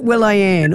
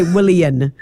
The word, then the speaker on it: Willian: So does he pronounce it Will-I-Ann or does he say Willian? How does he say it? – No, it's like William, but just with an N, so it's Willian.